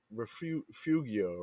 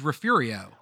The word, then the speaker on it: Refugio: If you're from South Texas, in Spanish it's refugio, but if you the locals will call it refurio.